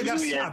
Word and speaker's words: Garcia: No, si dice Garcia perché è francese e la pronuncia è alla francese.